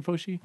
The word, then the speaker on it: Fauci: And from what I know after the fact, I think even Fauci, is that how you say his name? Anthony Fauci? – Yeah.